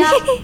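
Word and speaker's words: chi (七): Se pronuncia chi. Así el número 7. – Sí. Chi. Chi. – ¿Bien? La Q suena como una CH. Y La Q eh, junto con la I suena I, ¿sí? – Chi.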